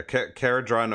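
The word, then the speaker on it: Kharadron: This is a universal objective that is coming in the Cadron Overlords. Is that how you say it? – Yeah, Caradron Overlords. Caradron Overlords.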